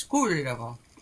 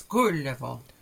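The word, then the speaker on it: Kullervo: And the hero, who was the basis for Tolkien's Turin Turambar, is Gullivo. That's Gullivo. – Gullivo. – Notice this being Finnish, that you have the accent on the first syllable.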